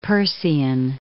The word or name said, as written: Peircean